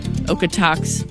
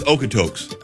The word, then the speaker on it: Okotoks: So between the Goldstream Day Use parking lot all the way up to about Okotos. I always say it wrong. Okotoks. What is it? – I think it's Okotoks.